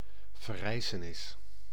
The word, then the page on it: sursaut: [syʁ.so]; France